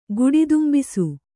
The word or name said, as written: ಗುಡಿದುಂಬಿಸು